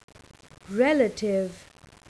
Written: relative

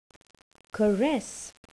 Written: caress

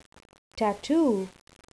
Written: tatoo

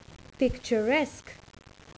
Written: picturesque

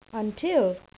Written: until

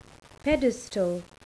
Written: pedestal

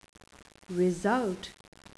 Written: result

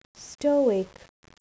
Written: stoic

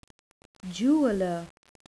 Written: jeweller

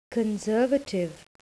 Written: conservative

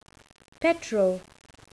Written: petrol

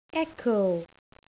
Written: echo

